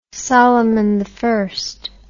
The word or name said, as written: Solimani